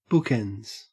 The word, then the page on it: bookends: Australian English